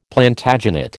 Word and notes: Plantagenet: American English

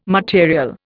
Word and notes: material: Indian

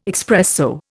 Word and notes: expresso: Indian

